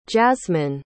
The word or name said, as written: jazsmine